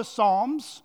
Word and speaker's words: Psalms: If you're newish to the Bible and you're asking, what are the Psalms? It looks like it's pronounced psalms. Well, it's the P silent there.